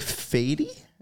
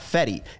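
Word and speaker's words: Fedde: Also in the rotation, Michael Soroka, Eric Fady. I think it's now. I saw the pronunciation list. It's not Fetty. It's Fady.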